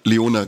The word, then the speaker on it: Leone: auch unter der Regie von Damien Leone. Oder Leone, wie spricht man den aus? – Leona, genau.